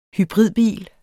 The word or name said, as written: hybridbil